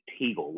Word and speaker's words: Teagle: It is Teagle, like the bird, but with the letter T in the front.